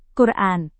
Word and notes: quran: Indonesian

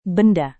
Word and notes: benda: indonesian

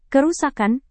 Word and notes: kerusakan: indonesian

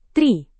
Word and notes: tri: indonesian

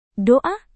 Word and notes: doa: Indonesian

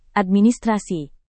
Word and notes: administrasi: indonesian

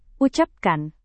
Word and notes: ucapkan: Indonesian

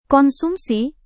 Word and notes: konsumsi: Indonesian

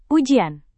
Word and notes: ujian: indonesian

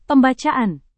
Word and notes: pembacaan: Indonesian

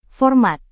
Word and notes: format: indonesian

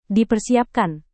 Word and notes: dipersiapkan: Indonesian

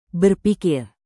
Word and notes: berpikir: indonesian

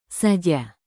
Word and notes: saja: indonesian

